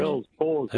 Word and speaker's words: Bell's palsy: What I'd like to know is if having Bell's palsy, I think that's how it's pronounced, yes. – Bell's palsy.